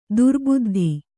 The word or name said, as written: ದುರುಬುದ್ಧಿ